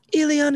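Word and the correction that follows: Elián: I know, that's how it's pronounced. You said it wrong, goddammit. You always talk about me saying things wrong. Elian Gonzalez, dude.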